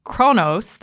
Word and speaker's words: Kronos: Anyway, getting back to our list, number 5 is Kronos. That's how it's pronounced in English. It's actually unpronounceable in its native language, unpronounceable to us.